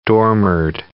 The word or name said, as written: dormered